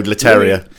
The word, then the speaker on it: Leterrier: In Louis Leterrier and Edward Norton, we had a team. – I think it's Leterrier. – Leterrier. – It looks like Terrier at the end. – Yeah, I'm going to go Leterrier.